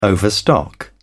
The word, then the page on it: overstock: British English